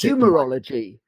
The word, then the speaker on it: Humorology: So you've written 5 books and you've got a new book in the background in process, which is Humanology, if I can pronounce Humorology. – It. – Humorology.